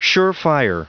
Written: surefire

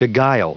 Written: beguile

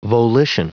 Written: volition

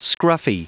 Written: scruffy

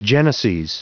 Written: geneses

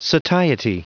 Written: satiety

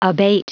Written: abate

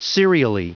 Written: serially

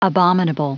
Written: abominable

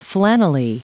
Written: flannelly

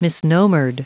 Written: misnomered

